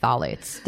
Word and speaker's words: phthalates: Like, I still can't say phthalates. Did I get that right? – Yeah, you did.